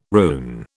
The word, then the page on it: rune: British English